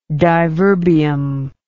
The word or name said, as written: diverbium